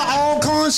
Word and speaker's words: Alcorn: You went to Alcorn State. What did you learn there? – I thank you for pronouncing that correct.